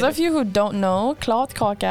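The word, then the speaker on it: kladdkaka: For me, it's definitely the klot kaka. I don't know if I pronounce it correctly. – Yeah. I know you said it perfectly.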